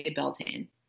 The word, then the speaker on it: Beltane: again, a couple different spellings, different pronunciations. Do you say it the same way I do? I say Beltane.